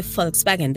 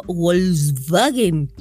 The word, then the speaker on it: Volkswagen: Ahora soy la voz, la voz de Volkswagen, de los comerciales. Y no digo Volkswagen. – Volkswagen. Y esa es la pronunciación que tengo que hacer.